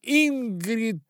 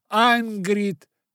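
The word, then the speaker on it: Ingrid: Имя Ингрид будут произносить как Ангрид.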